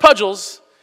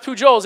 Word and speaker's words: Pujols: And it's spelled P-U-J-O-L-S, but it's not pronounced Pudgels, and it's not even pronounced Pujols, it is pronounced Pujols.